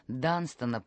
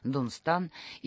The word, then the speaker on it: Данстон: Знаменитая английская благотворительная организация, носящая имя святого Данстона, по-русски это имя раньше часто произносилось как Дунстан, и занимающаяся помощью слепым инвалидам войны, отмечает свое 90-летие.